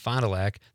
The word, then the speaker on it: Fond du Lac: Gillies and Fond du Lac, yes. – Okay, Gillies and Fond du Lac.